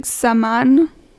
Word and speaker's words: Xaman: I don't know how to pronounce it. I pronounce it Xaman. It's with an X-A-M-A-N.